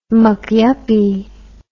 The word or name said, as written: McYuppie